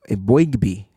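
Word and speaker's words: Eboigbe: Is it Mboigbe? Mboigbe? – Mboigbe. – I like that.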